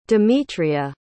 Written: demetria

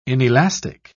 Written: inelastic

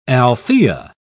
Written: althea

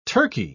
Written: turkey

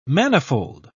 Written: manifold

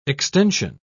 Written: extension

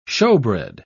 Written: shewbread

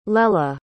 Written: lelah

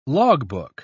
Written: logbook

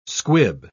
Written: squib

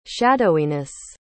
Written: shadowiness

